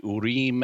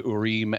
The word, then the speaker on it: Urim: In the Old Testament, the high priest had a couple of objects that were known as the Urim and Thummim, or as Americans say, the Urim and Thummim.